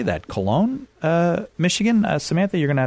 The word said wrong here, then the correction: Colon: Nope, it is just like the punctuation mark, colon.